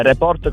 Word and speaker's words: Report: È Report con l'accento però sulla O.